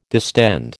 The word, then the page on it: distend: American English